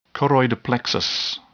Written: choroid plexus

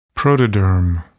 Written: protoderm

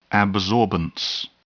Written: absorbance

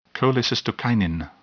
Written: cholecystokinin

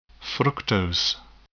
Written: fructose

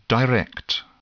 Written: direct

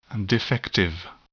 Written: defective

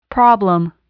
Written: problem

